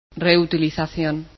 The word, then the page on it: reuse: Spanish